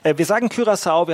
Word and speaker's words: Curaçao: Wir sagen Curaçao, wir haben ja in der ARD ein wunderbares Tool, eine Aussprache-Datenbank, wo Expertinnen und Experten die Köpfe zusammengesteckt haben. Da steht ein leichtes Ü drin, deswegen haben wir uns jetzt darauf geeinigt.